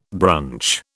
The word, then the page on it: brunch: British English